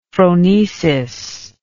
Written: phronesis

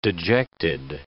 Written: dejected

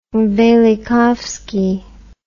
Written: velikovsky

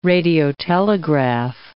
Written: radiotelegraph

